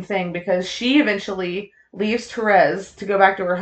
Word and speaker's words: Therese: Therese? – Thank you. So, or Therese. – Therese, sorry, the way it's pronounced.